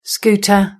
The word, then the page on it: scooter: British English